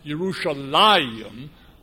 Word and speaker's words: Yerushalayim: But you have technically a Kyrie perpetuum, no marginal note, but it's always pointed as though it should be pronounced Yerushalayim, which is a dual, two Jerusalems in the Hebrew.